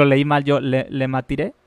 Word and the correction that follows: Lemaître: Le maitré. – Según yo se pronuncia Lemaître.